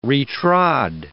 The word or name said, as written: re-trod